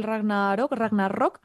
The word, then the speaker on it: Ragnarok: Que es el Ragnarok, se pronuncia con R fuerte, yo lo he pronunciado Ragnarok toda la vida.